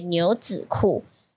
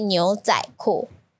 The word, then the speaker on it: 牛仔裤: 但是这个 the middle word, 牛仔的仔, it has another pronunciation, 是子。 So the education department, they would come and say, this year you should pronounce 子, so you should say 牛子库. But next year they will say, oh, no, you should say 牛仔库.